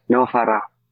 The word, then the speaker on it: Novaria: And my brother recommended the name, and I was like, it might be hard for Americans to pronounce our R because it's Novaria.